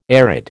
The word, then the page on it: arid: American English